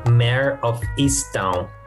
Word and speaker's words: Mare of Easttown: Chegou chegando uh, é, Mare of Easttown.